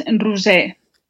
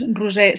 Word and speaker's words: Roser: It is Rusei. – And your last name? – Segarra. It is Rusei Segarra.